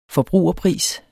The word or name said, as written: forbrugerpris